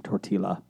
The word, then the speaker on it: tortilla: You don't pronounce the L in tortilla. – Tortilla.